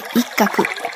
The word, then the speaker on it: 一鶴: 香川はうどんも美味しいですが、鳥大好きの私としては、一鶴と書いて一角と読む骨付き鳥のお店をおすすめしたいです。